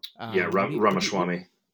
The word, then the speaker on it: Ramaswamy: What do you suppose Ramaswamy, am I saying it correctly? – Ramaswamy.